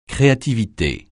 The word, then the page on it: créativité: French